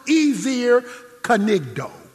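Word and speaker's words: ezer kenegdo: The Hebrew word helper suitable is pronounced Ezer Kanigdo. That's how it's pronounced in Hebrew.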